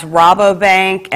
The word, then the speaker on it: Rabobank: Okay, I understand it's pronounced Rabobank, and checks can be made payable to the PNF Fire Victim Relief Fund.